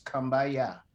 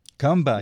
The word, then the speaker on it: kumbaya: I can understand that, but the traditional pronunciation in the gullah community, if you use your traditional gullah language, is kumbaya. – Kumbaya. – You know, or come by here. Kumbaya.